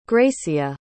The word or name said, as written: grecia